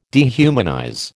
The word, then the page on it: dehumanize: American English